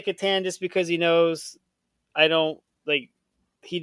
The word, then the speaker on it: Catan: Catan. It has to be Catan.